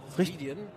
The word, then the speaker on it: Ceridian: Ja, ich bin weiterhin auf der Messe Zukunft Personal in Köln und bin jetzt am Stand von Ceridian. – Ziridian. Ziridian. Ziridian. – Ja, okay, das wird anders ausgesprochen, Entschuldigung.